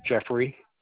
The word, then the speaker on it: Jeffrey: Jeffrey. Dunlap. D-U-N-L-A-P. – Can you spell it for me? Spell your first name. J-E-F-F-R-E-Y.